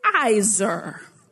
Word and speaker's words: Ezer: And the Hebrew word for help or helper is Ezer. It's spelled E-Z-E-R, but pronounced A-Y-Z-E-R.